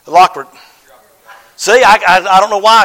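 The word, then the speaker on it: Euroclydon: Verse 14 says, but not long after there rose a tempestuous wind called an Ecuador. I promise you, at home, I learn how to pronounce these things, and I get to church, and I cannot pronounce them. Eurocladon. Yes, thank you.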